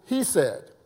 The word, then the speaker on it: hesed: Now, the Hebrew word for mercy, according to Strong's Concordance, is pronounced hesed. That's H-E-S-E-D.